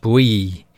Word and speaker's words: bouillie: And the name bully beef comes from the French bouillie, meaning boiled, but it's spelt B-O-U-I-L-L-I. So it looks like bully, and that's obviously where it comes from, the name.